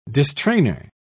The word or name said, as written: distrainer